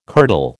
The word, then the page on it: kirtle: American English